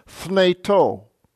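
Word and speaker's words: fneto (θνητῷ): Verse 12, me un basilueto, he hamartia, en to fneto, accents on the last syllable, fneto.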